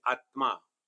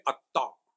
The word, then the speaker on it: Atma: He cannot pronounce a conjunct consonant. Conjunct consonant is a combined letter. Like Atma. The half and more complete Atma. Bengali will say Atta.